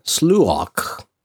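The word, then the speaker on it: sluagh: And then there is the sluok. – Definitely pronounced right. – Yes. You heard me with the guttural sound. That means I pronounced it right.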